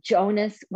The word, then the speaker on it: Jonas: It's Jonas with an A, not Joan.